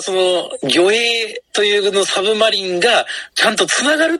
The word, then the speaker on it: 魚影: はい、魚影と魚の影と書いて。 – サブマリン。